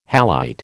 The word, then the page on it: halite: American English